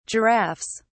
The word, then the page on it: giraffes: British English